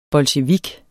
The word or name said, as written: bolsjevik